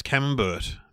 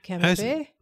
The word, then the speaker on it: camembert: A lot of people, there was a lot of talk about how I pronounce camembert. Apparently that's oh. – wrong. – Yeah, I did. Tom Peterson, friend of the show, actually picked me up on that and said, why on earth did you get away with saying camembert? And I said, well, you know, we're not in France. He can say it however he wants. – How do you say it? – Camembert. – Oh, of course. Camembert, not camembert. Camembert.